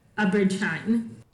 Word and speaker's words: Abrid Shine: Again, it wasn't really very much available until later, and then I realized it was um, Abrid Shine. Am I saying his name correctly? Uh, uh, uh I pronounce it as Abrid Shine.